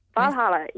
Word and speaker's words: Valhalla: Valhalla, yes.